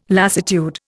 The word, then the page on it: lassitude: Indian